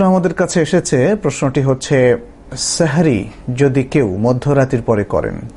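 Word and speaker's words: সাহুর (سحور): সাহরি মধ্যরাতের পরে করলে কোনো অসুবিধা নেই, তবে সাহরির ক্ষেত্রে, যেটাকে আরবিতে সাহুর বলা হয় মূলত, সাহার অথবা সাহুর, বাংলায় যদি আমরা সাহরি বলি, শব্দটার আরবি উচ্চারণ হচ্ছে সাহুর, এই সাহুর যদি কেউ মধ্যরাতের পরে দিন নেন অর্থাৎ পানাহার করেন তাহলে কোনো অসুবিধা নেই, যদি তিনি দেরি করেন সেটাই হচ্ছে সুন্নাহ।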